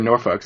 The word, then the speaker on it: Norfolk: You're saying it wrong. It's Norfolk. – Oh. – It's supposed to be said Norfolk. So you say we don't drink. We don't smoke. Norfolk. Norfolk.